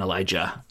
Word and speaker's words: Elijah: Oh no, it's one's an SH and one's a J. – Oh, like Elisha. – Yeah. Elisha and Elijah.